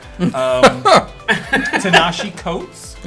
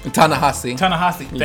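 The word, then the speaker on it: Ta-Nehisi Coates: Tinashe Coates. – Ta-Nehisi.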